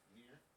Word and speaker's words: Nier: Fuck Nia. – Fuck Nia. – Nia. It's not how you pronounce it. Nier.